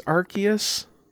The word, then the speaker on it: Arceus: How would you say that word? People that play more Pokemon than I do. I think it's Arceus, but I sure.